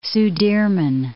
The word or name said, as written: Sudirman Range